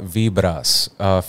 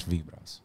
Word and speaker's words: Vibras: It's called Vibras, featuring Nas Lover, which is vibes in Spanish, Vibras.